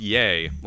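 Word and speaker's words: Ye: it's just Y E pronounced Yay, like Kanye, but just Yay.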